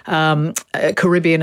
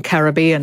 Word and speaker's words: Caribbean: So that's where English stress is moving, and that's why we have also this alternation between Caribbean and Caribbean.